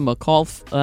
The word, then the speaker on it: McAuliffe: Well, on this day in history, 41 years ago, it was in 1984 when President Reagan announced that school teacher Krista McAuliffe, I don't remember. Do you remember how to pronounce that? – McAuliffe, yeah.